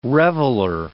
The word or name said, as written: reveller